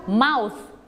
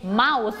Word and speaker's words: mouth: mouth ลงท้ายด้วย th ออกเสียงให้ชัดเจน mouth